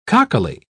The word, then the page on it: cockily: British English